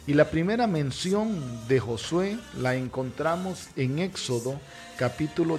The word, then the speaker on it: Josué: En hebreo se pronuncia Joshua, Josué, Joshua, que es el mismo significado de Jesús en el Nuevo Testamento.